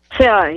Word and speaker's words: Sara: Hello, it's Sarah Corpy. – Hi, Sarah, did you say? – Excuse me? – Did you say your name is Sarah? – Sarah, yes. S-A-R-A, no H.